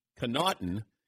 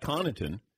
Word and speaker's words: Connaughton: The Milwaukee Bucks uh, star dunker, Paulie went and found uh, Pat Connaughton, or Pat Connaughton, and uh, he is going to tell us how.